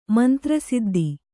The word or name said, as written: ಮಂತ್ರಸಿದ್ದಿ